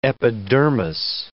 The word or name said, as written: epidermis